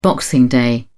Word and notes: boxing day: British English

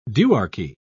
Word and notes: duarchy: British English

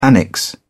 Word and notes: annexe: British English

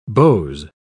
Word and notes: beaux: British English

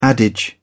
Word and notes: adage: British English

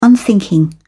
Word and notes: unthinking: British English

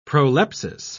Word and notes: prolepsis: British English

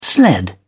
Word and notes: sled: British English

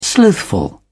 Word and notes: slothful: British English

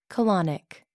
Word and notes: colonic: American English

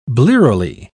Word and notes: blearily: British English

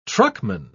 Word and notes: truckman: British English

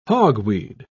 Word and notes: hogweed: British English

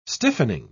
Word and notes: stiffening: British English

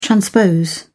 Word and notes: transpose: British English

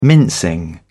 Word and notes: mincing: British English

